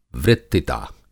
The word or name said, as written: वृत्तिता